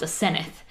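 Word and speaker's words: Asenath: Well, they call her Osenna, but we have a friend whose name is... – spelled the same way. – Spelled the same way, but it's pronounced Asenneth.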